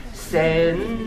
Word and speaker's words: sensei: Quand c'est sense, donc si on prononce rapidement sense, mais quand on chante, sensei. Et quand on parle, on ne prononce pas le i en plus. Hein. C'est, ça devient une longue.